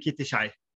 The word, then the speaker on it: Kitty Chai: Jag måste bara fråga, uttalas ditt förnamn Kitty Chai? – Jajamän. Right on.